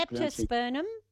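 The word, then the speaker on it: Leptospermum: A leprosternum. Have I got the name right? – Yeah, leprosternum. Yep.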